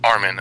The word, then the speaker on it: Armin: Did I pronounce that right? Armin or Armin? – Just think as an Armin Hammer, you know? – Okay. Armin, okay. Had attended the event for discussion.